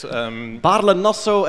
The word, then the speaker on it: Baarle-Nassau: Baarle-Nassau and Baarle-Hertog, yes.